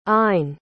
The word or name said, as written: ayn